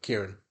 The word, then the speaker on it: Kirin: K-I-R-I-N. – Oh, K-I. – It's like Karen, but Kieran.